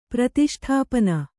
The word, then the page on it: ಪ್ರತಿಷ್ಠಾಪನ: Kannada